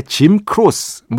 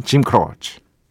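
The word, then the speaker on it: Jim Croce: 자, 오늘은 과거에 짐 크로스, 뭐 이렇게 발음하던 DJ들도 있었던 바로 그분, 짐 크로치.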